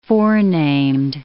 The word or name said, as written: forenamed